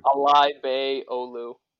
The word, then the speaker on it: Alaybeyoglu: Alay Bey Olu, or just A10.